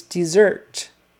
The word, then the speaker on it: dessert: Desert versus dessert. Similar sounds, but the accent is on the second syllable, dessert.